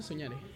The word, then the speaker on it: sognare: Eh, división minúscula, soñare. Soñare. Pero se escribe con G, sognare.